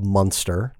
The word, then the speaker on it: Munster: In a town called Munster, not like the cheese.